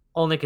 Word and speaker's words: Olynyk: And Olynyk could play a big part in that. And I think if there's anything, if we want to talk about underrated players, Covington is one of them. Olynyk is another.